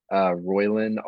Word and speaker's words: Roiland: Roiland, R-O-I-L-A-N-D.